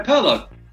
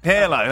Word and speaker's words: Pirlo: Andrea Pirlo. – Pirlo.